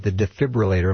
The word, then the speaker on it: defibrillator: And the defibrillator, am I saying that right? – Yes.